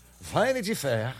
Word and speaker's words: Vanity Fair: Vanity Fair.